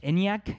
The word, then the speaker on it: Enyaq: Enyaq, Enyaq.